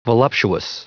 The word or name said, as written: voluptuous